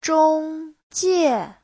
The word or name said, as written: zhong1jie4